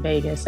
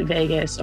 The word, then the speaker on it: vagus: And I'm not saying vagus as in Las Vegas, but vagus, or you could say vagus, because it's spelled V-A-G-U-S.